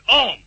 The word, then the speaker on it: homme: The French for man is spelled H-O-M-M-E and is pronounced om. The H is silent.